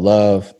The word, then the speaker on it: LXVE: I have a brand that I've created called Love. It's spelled LXVE, stands for Love and Value Everything and Everyone.